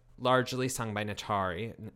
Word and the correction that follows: Naturi: Sorry, am I pronouncing it right? – Uh, Naturi. It's Naturi.